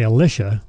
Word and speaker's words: Elisha: So a lot of lectors will say Elisha, which that way you can at least differentiate between Elijah and Elisha. But yeah. It's pronounced Elisha. That's how it's pronounced.